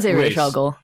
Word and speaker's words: Ra's al Ghul: We can say Ra's. Ra's al Ghul. – Oh, that's right. That's the correct way. – That's the correct way to say it.